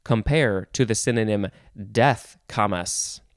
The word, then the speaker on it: camas: Next is camas, C-A-M-A-S, or two S's, or, oh, interesting, you could spell it and pronounce it Quamish, with a Q-U-A-M-A-S-H. So camas or Quamish.